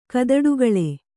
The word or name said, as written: ಕದಡುಗಳೆ